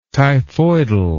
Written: typhoidal

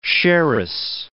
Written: sherris

